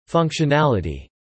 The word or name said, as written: functionality